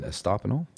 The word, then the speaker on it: Estopinal: So the voices that you're hearing this morning, we have Angie Gillette and Edmi Mendez and Diane Estopino. Did I say that right? – Yes. – Hey.